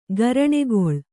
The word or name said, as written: ಗರಣೆಗೊಳ್